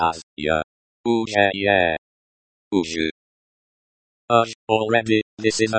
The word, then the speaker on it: уже: The, ZH, is like a, J, in, jump, so pronounce as, уже.